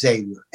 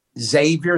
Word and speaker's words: Xavier: He called Xavier, like Xerox, like the xylophone, Xavier. That's how you pronounce the X-A-V-I-E-R. – Xavier. – He called them Xavier, because that's how you put down Xavier. You call them the breakfast school, E-G-G-S-A-V-I-E-R. Yeah. So they call us eggs.